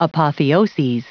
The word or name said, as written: apotheoses